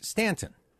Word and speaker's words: Staunton: So actually, the name of the city that you just passed, although it's spelled Staunton, is pronounced Stanton.